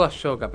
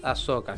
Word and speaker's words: Ahsoka: O yo le digo Ashoka, pero es Ahsoka, así que nada, Ahsoka Tano para pronunciarlo bien.